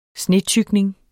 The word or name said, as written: snetykning